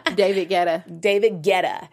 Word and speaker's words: David Guetta: And he sings When Love Takes Over by Kelly Rowland and David Guetta. If I'm saying his name right. – David Guetta.